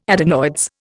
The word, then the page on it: adenoids: Indian